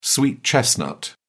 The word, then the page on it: sweet chestnut: British English